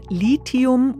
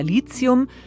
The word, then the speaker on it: Lithium: So, und wer sich jetzt die ganze Zeit gefragt hat, wie heißt es denn nun, Lithium oder Lithium? Klare Antwort, ja. Aussprache Duden sagt, geht beides.